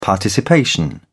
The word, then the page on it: participation: British English